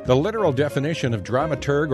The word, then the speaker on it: dramaturg: Now, as intrigued as Jim was about the term dramaturg, so was I. Now, it's sometimes pronounced dramaturge, sometimes spelled drama and then T-U-R-G or drama T-U-R-G-E.